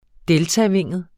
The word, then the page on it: deltavinget: ˈdεltaˌveŋˀəð; -ˌveŋəð